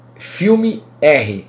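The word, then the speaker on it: Filmr: Eu vou indicar para vocês aqui um chama Filme R, Filmer.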